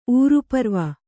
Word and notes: ಊರುಪರ್ವ: Kannada

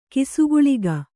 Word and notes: ಕಿಸುಗುಳಿಗ: Kannada

